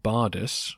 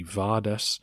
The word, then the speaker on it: Bardas: The name Bardas was a popular Armenian name. It can be pronounced with a V, so in some texts you see Vardas, and the similar Bardanes or Vardan.